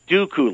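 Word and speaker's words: Duqu: It's pronounced Dooku, like the Count Dooku. – in Star Wars Episode 2.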